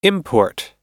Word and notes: import: American English

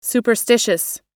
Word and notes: superstitious: American English

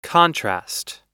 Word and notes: contrast: American English

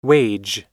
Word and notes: wage: American English

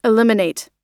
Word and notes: eliminate: American English

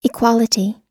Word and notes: equality: American English

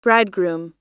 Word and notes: bridegroom: American English

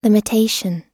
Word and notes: limitation: American English

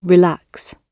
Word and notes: relax: British English

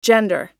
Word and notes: gender: American English

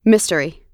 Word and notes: mystery: American English

